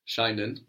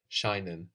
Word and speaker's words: scheinen: Scheinen, of course. – That's spelled Scheinen. So SCH gives us the sh sound, shinen.